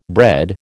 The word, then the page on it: bread: American English